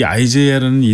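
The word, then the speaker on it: Isaiah: 그런데 이제 발음상 이게 표기법이 국립국원에서도 현재 아이제이아로 표기가 이제 확정이 되어 있고요. 그래서 네. 좀 약간 낯설지만 뭐 아이제이아 제이 톰슨 이렇게 부르게 됩니다.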